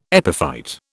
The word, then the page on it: epiphyte: British English